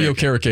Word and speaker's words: Okereke: Okereke. That's what it is. Bobby Okereke is how you say it.